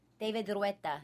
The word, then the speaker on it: David Guetta: David Guetta.